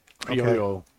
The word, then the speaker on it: Criollo: So, or did I say that right? – That's how I say it. – Criollo. Okay. – Criollo. – Criollo. – I'm white. Don't hold it against me. – No, you got it right.